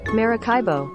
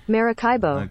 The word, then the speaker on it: Maracaibo: Maracaibo. – Maracaibo. That's the lake, right? – Maracaibo.